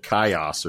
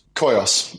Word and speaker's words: Koios: Uh, so Koios, did I say it right? Chad said Kaios or something. Is it Koios? – Koios.